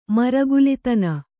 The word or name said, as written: ಮರಗುಲಿತನ